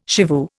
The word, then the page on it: shivoo: Indian